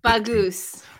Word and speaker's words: bagus: So I could be completely off base, but the the Japanese pronunciation of it has been bagus.